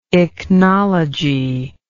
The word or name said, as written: ichnology